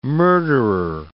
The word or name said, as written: murderer